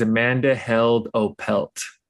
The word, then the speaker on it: Amanda Held Opelt: And today, our very special guest is Amanda Held Opelt.